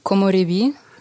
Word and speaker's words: Komorebi: Wie wird das ausgesprochen? Was für eine Sprache ist das? – Komorebi, das ist japanisch.